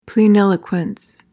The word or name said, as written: pleniloquence